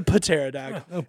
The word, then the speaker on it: pterodactyl: Pterodactyl spelled with a P. It's a pterodactyl.